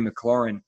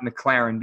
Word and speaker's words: McLaurin: And it's not McLaren because it has a U in it, even though I love McLarens. So they want to call him F1, but that's not how you say it.